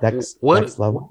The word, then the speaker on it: DEXLVL: Featuring Dex Level, I believe is how you say the name. D-E-X-L-V-L. That's how I'm saying it. Dex Level.